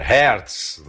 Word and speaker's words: Hertz: Ele mesmo diz que a pronúncia é Hertz, né? Mas que como o pai dele o chamava de Hertz, ele quer que todo mundo o chame de Hertz Dias, que é do candidato do PSTU.